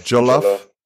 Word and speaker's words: jollof: But what it, Yolof, did I say it right? Yolof rice? – Jollof rice? – Yeah.